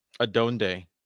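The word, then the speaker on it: adónde: How do I say it? – Adonde. – Adonde Estas.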